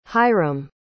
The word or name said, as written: hiram